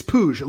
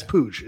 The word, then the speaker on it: Puig: Ricky Puig, is that the pronunciation? – It's Puig. It looks like Puig. And then again, as an LA guy, former Dodger fan, we had Yasael Puig, but it's Puig. It's the Puig. Pronunciation we've been told.